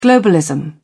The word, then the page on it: globalism: British English